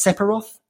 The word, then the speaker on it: Sephiroth: I assume that's Sephiroth.